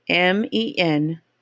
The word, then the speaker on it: Menominee: Federally Recognized Tribes, Menomi. And I know I did not say that right. So let me pronounce it. Let me tell you how it's spelled. Menominee.